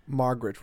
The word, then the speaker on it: Margot: And I was kind of confused by Robbie's character, Margaret. Is it Margot? Margaret? – Margot Robbie, I think is how you say her name.